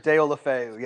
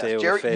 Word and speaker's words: Deulofeu: I also paid $5 for Gerard Deulofeu, or however you say his name. – Gerard Deulofeu, yes. Deulofeu.